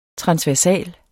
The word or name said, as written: transversal